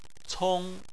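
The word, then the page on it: cong: tone 1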